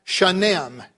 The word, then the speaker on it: shanim: And she tells me that the Hebrew word is pronounced shanim, S-H-A-N-I-M.